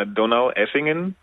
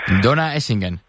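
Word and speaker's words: Donaueschingen: Sí, se llama como, la ciudad se llama Donau-Eschingen, es como Donau, es como traduciendo como Danubio, el río de allá, y Eschingen de verdad, no sé, pero sí, total, Donau-Eschingen. – Donau-Eschingen.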